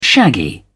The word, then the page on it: shaggy: British English